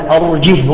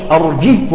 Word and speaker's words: أرجه: أرجه وأخاه، الهاء قبلها مكسور وبعدها أرجه ومفتوحة، ولكنها لا تمد بل تنطق ساكنة وصلا ووقفا. قال تعالى: أرجه وأخاه، أرجه وأخاه بالسكون.